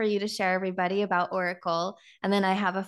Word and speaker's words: Orykl: So it's pronounced Orykl, maybe slightly different, but, you know, you get it.